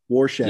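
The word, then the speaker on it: Rorschach: Did I say his name wrong? – Warshak. – Yes. – Warshak. – It's Warshak. – like a Warshak test.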